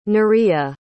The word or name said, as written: nerea